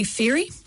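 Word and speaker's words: Fairey: Just search for Julie Ferry. That's spelled F for Fred, A-I-R-E-Y.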